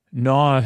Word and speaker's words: gnaw: Gnaw has a G, G N A W. We've seen TH and silent C.